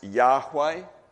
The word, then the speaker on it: Yahweh: and a lot of people think that the vowels, if they're put in, the name was probably pronounced Yahweh. When German translators were translating this word, they used a J because in German J is pronounced Y, so that's Jehovah in German. So in our English language we will say Jehovah.